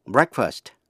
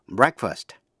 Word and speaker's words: breakfast: Nhưng khi là danh từ chỉ bữa ăn sáng, breakfast, thì EA phát âm là E như trong chữ bed. Quý vị nghe lại. To break, phát âm A. Danh từ breakfast, phát âm E. Break, breakfast. Break, breakfast.